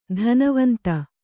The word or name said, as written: ಧನವಂತ